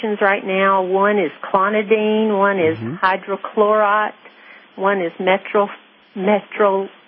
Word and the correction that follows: metoprolol: I can't pronounce it. Metoprolol. – It's called metoprolol.